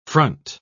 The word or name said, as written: front